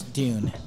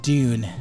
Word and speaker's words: Dune: It's Dune. So Dune. – Yeah. Dune.